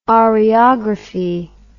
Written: oreography

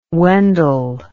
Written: Wendell

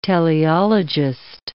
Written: teleologist

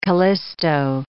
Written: Callisto

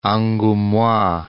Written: Angoumois

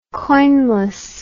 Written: coinless

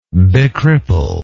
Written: becripple

